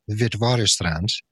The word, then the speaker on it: Witwatersrand: And also that you bring to the table a bachelor's and master's in psychology from, I believe I can pronounce it correctly, University of Witwatersrand in South Africa, correct? – You got it.